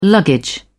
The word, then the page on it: luggage: British English